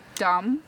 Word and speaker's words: dumb: Stupid, D-U-M-B. Dumb. – Dumb. – Dumb, D-U-M-B, but you don't pronounce the B, dumb.